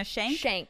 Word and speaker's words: Shayk: Irina Shayk? – Shayk.